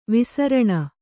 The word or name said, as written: ವೈಸಾರಿಣ